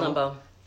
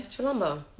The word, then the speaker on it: Chilombo: Chilombo. – Say it again? – Chilombo.